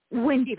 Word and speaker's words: Wendy: Wendy, like Peter Pan, W-E-N-D-Y.